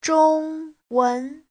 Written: zhong1wen2

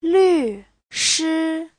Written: lv4shi1

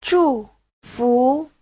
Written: zhu4fu2

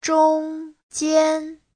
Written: zhong1jian1

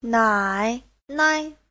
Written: nai3nai5

